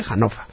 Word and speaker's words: Hannover: Bueno, y es la segunda o tercera ocasión que sucede en la mayor feria de informática que se celebra en Hannover, sí, se pronuncia Hannover, se escribe Hannover, pero bueno, como sé que estáis acostumbrados a eso, pero en alemán se dice Hannover.